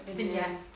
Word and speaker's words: vignette: Vignette, is that how we pronounce it? – Vignette. – I was close.